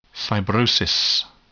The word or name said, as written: fibrosis